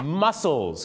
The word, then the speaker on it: mussels: ต่อไปก็จะเป็นเรื่องราวของหอยนั่นเองครับนะหอยเนี่ยนะครับก็แน่นอน shells หรือว่า shellfish เนี่ยนะฮะก็จะมีอะไรบ้างครับที่เราทานกันประจำครับหอยแมลงผู้ mussels ครับที่แปลว่ากล้ามเนื้อเหรอครับที่จริงออกเสียงเหมือนกันแต่สะกดกันไม่เหมือนกันนะครับ